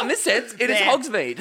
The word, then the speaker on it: Hogsmeade: It's pronounced Hogsmeade.